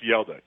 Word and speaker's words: Hjalte: It's Yelder Froholt. Now, you would never know it's pronounced Yelda. It's like H-J-A-L-T-E.